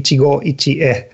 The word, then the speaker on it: Ichigo ichie: but the Japanese concept Ichigo Ichi, is that the right way to say it? – It's Ichigo, I think it's okay. In Japanese, would be Ichigo Ichie.